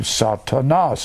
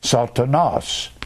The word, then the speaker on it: Satanas: Satan in the Greek is the word satanos, S-A-T-A-N-O-S. Or N-A-S, excuse me. Satanas. It's pronounced Sa-ta-nos. Satanas.